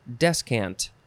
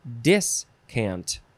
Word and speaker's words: descant: Yeah, descant. D-E-S-C-A-N-T. First form. You could also spell it and pronounce it. Discant.